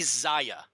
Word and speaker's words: Czaja: And, you know, I don't think it's Zaja. You know, now that I look at it and stare at it, it's probably Zaya is probably how you pronounce it.